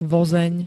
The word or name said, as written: vozeň